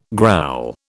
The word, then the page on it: growl: British English